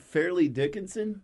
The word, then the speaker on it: Fairleigh Dickinson: How do you say it? Fairleigh Dickinson? – Yeah.